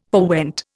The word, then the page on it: forewent: Indian